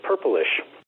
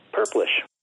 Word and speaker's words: purplish: What I noticed was when you change purple to Purplish, you drop the E. Therefore, it is not a three-syllable word, Purplish, as you like to pronounce it. It becomes a two-syllable word, just Purplish.